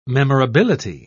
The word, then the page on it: memorability: British English